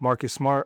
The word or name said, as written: Marcus Smart